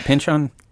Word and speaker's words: Pynchon: So you're coming at this more from a Thomas, say his last name, is it Pynchon? – Yes. – Did I say that correctly? – I've heard it 3 or 4 times, but I'm going to go with The Simpsons. – Okay. – And they say Pynchon.